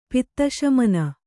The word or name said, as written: ಪಿತ್ತಶಮನ